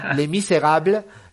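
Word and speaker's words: Les Misérables: porque yo sé que quiere que lo pronuncie en francés. Lo voy a hacer. Le Misérable, eh, una película hermosa, hermosa.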